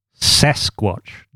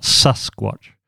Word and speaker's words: Sasquatch: My Adelaide private schoolboy accent has betrayed me. Sasquatch, not Susquatch, which rhymes with Musquatch, Sasquatch, Masquatch.